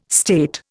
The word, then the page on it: state: Indian